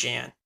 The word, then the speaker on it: Jeanne: Did you call her Jeanne? – That's, if I remember correctly, that's how her name is actually pronounced. It's Jeanne.